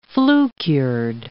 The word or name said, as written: flue-cured